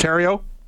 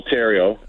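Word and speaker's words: Thériault: Well, no, help me out on the pronunciation. So it's pronounced Terrio? – Yeah, Joel Terrio.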